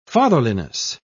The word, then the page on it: fatherliness: British English